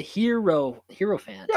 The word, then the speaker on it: Hierophant: Hierophant. – How do you say that? – The Hierophant. – Yeah, I did say it right.